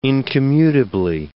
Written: incommutably

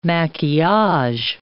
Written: maquillage

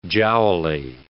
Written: jowly

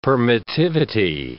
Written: permittivity

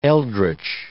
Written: eldritch

